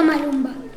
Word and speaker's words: Maluma: Bueno, vamos a escuchar un tema de Maluma. De Maluma. – Maluma. – Bueno, no me sale pronunciar. – No, Maluma.